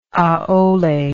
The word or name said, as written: aole